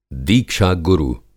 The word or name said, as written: दीक्षा गुरु